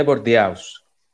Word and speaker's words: Bordeaux: Bordeaux 4.